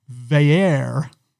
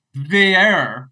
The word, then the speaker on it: Veyer: We've been pronouncing Christian Velu's name wrong for 2 years. It's Christian Veyer. – Veyer. – Yes. – Like Veyer, like Air Canada.